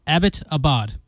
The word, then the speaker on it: Abbottabad: The city was named after British major Abbott. So its pronunciation is simple. Abbottabad. There you go.